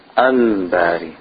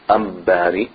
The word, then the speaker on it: Al-Bari: When you have Alif Lam followed by the letter Ba, do we pronounce the Lam or do we skip over the Lam? We pronounce the Lam. That's what Al-Qamariya means. So for example, we say Al-Bari. That's one of the names of Allah subhanahu wa ta'ala. Al-Bari. We don't say Ambari.